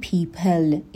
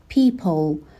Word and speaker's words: people: خب برای تلفظ کلمه people دقت داشته باشیم که نگیم people, یعنی یک صدای ا ندیم آخرش, صدای مشابه او داره, people نیست, people هم نیست, یه چیزی بینش, people, به این صورت for people who like her,